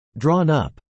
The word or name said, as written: drawn up